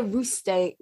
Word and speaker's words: Rousteing: Oh, Olivier Rousteing.